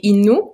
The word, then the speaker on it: Innu: oui, en fait, on dit Innu.